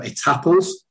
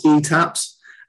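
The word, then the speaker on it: Étaples: NCOs at, I apologise for the pronunciation because I've only ever read it, but ETAPLS, which I think can be pronounced ETAPS, were particularly detested by every soldier who encountered them because they displayed the utmost zeal for spit and polish.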